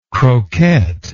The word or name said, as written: croquette